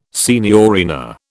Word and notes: signorina: British English

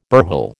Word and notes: burhel: American English

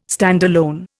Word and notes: standalone: Indian

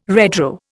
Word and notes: redraw: Indian

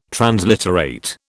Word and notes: transliterate: British English

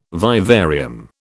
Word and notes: vivarium: British English

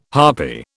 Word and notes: harpy: British English

